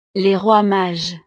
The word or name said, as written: les rois mages